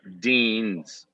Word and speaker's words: Deans: Deans. We have to pronounce the S. – Yeah, make sure the S is in there.